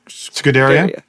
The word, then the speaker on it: Scuderia: It's the Scuderia? – Yes.